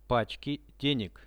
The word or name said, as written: pachki deneg